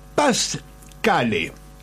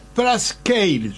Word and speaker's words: Parscale: Paz separado, Cale. Si alguno tiene ganas y lee el diario y lee la entrevista, lo va a encontrar. No es Parscale, es Brad Parscale. Parscale sería una pronunciación que seguramente no es perfecta, pero los diarios están escritos para tujes, de todos.